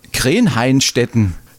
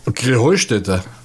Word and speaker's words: Krähenheustätte: Ja, ihr sagt immer Krähenhainstetten. Und dabei heißt es doch Krähenheustätte, oder wie? – Krähenheustätte. – Ja, genau. Und das, die Betonung auf dem Heu.